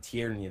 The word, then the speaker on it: Tierney: I'm a fan of Brandon Tierney. That's how you say his name.